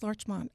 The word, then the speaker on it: Larchmont: Where is Larchmont? Am I pronouncing that right? – Yes, Larchmont.